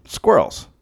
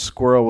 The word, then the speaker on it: squirrel: So this dude, Gregory, sent me an email about squirrels. – Yeah. – And also someone on Twitter wrote and said, it's cute the way Americans pronounce squirrel with just one syllable.